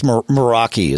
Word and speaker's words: Meraki: I think it's Meraki is how Meraki pronounce it.